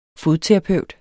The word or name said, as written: fodterapeut